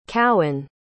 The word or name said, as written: cowen